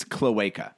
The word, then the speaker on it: cloaca: By the way, we were mispronouncing it. It's cloaca.